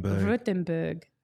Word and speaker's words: Württemberg: Baden-Württemberg is how you say it. Württemberg. – I was wrong.